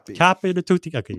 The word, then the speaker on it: capo di tutti capi: the capo di capi. How do you say that? – Capo di tutti capi.